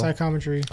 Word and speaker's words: psychometry: psychometry, it's called. Did I pronounce that correctly, yes? – Yes, psychometry.